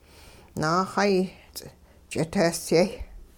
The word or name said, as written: naaxay ch'iteesyay